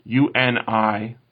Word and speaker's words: unionized: And if it was how you pronounce the word UNIONIZED. Which is, you guys chemists? – Nope. – Unionized? – Unionized. And regular people would say unionized.